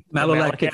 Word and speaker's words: malolactic: So I'm starting to play around with, you know, the malto, I'm going to say it wrong, the maltic acid or whatever you have to do for it. And malolactic fermentation.